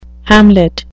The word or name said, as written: hamlet